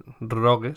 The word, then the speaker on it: rogue: roje, rogue sería, ¿no? La pronunciación correcta.